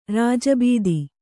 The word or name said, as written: ರಾಜಭತ್ತ